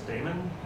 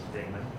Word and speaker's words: Damon: My name is Damon, and that is with two syllables. People love to add an extra syllable to my name. They call me Damien, but my name is Damon.